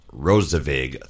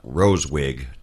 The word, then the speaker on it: RoSewig: And if you ever look at the spelling of Rosevig, it's capital R-O, capital S-E-W-I-G. The reason for the capital S was because he wanted to ensure that his name was pronounced Rosevig, three syllables, and not Rosewig, two syllables.